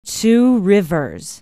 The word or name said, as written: two rivers